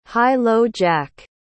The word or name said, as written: high-low-jack